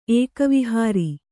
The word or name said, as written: ಏಕವಿಹಾರಿ